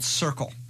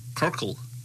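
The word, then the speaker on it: Cyrkle: Now, the band was actually a group called Circle, spelled C-Y-R-K-L-E. C-Y-R-K-L-E. – Yeah, that's why you didn't do well. What's that? Kirkle? – No, Circle. – Kirkle? – No, it's still a soft C.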